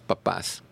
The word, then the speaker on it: papás: Un día, como le digo yo, el día de los poteros, el día de los papas, con acento en la última, el día de las papás.